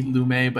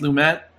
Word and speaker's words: Lumet: I'm also not an expert on Sydney, and I always pronounce it Sydney Lumet, but I think people tell me it's supposed to be pronounced Lumet.